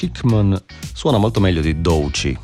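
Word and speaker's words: Doechii: Doici.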